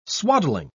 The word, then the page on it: swaddling: British English